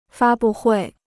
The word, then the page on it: 发布会: fā bù huì